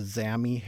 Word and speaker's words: Xami: It's called the Zami Hairstreak. That's spelled X-A-M-I.